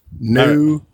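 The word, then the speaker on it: Nouveau: Now, how do you say the open source NVIDIA driver? – The Nauvoo driver? The Nauvoo driver? The. – Perfect.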